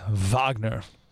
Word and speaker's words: Wagner: That is actually pronounced Wagner, named after the composer.